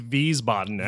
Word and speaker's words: Wiesbaden: How do you pronounce Wiesbaden? – It is Wiesbaden, actually. Wiesbaden. So I was listening earlier, and you did have it.